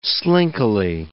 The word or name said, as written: slinkily